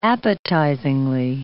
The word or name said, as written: appetizingly